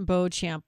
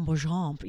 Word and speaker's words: Beauchamp: Now, you did mention Marjon Beauchamp, which, by the way, why is it pronounced like that? I feel like it should be like Marjon Beauchamp. You know, like it's kind of like the American version of it a little bit.